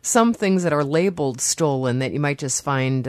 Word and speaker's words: Stollen: The is it Stollen? Is that how you pronounce that? – What's Stollen is how you say it here. It's over in Germany, it's pronounced Stollen.